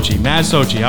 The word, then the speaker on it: Matt Socci: Matt Socci.